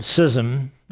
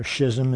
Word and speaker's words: schism: This is the word schism, or schism as some people pronounce it.